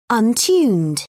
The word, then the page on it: untuned: British English